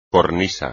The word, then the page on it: cornisa: espagnol